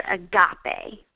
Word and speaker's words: agape: The word we are looking at in these verses in Galatians is the word agape, okay? Agape, and that is spelled A-G-A-P-E.